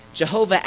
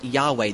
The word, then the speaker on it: Yahweh: Jehovah actually is a linguistic mistake that the Jews made in trying to pronounce this word Yahweh, this name for God.